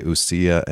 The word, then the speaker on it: ousia: So those terms are in Greek, and correct me if my pronunciation is not what you prefer, but I say ousia and hypostases.